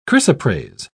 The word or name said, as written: chrysoprase